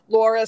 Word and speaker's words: Lauria: Laura spelled L-A-U-R-I-A. The I is silent.